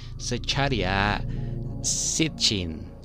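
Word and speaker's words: Zecharia Sitchin: Así que bueno, este señor es un experto en lenguas muertas, perdón por, este, por esta mala pronunciación, Secharia Sitchin. Ah, mira, bastante fácil.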